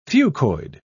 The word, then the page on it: fucoid: British English